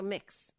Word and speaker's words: Mx: It is pronounced Mix. Thank you. Okay, so Mix.